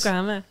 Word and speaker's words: Yokohama: Yokohama, ja.